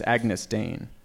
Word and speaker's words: Agyness Deyn: The name looks harder to pronounce than it actually is. – Well, why don't you say it? It's just it's Agyness Deyn. – Agyness Deyn.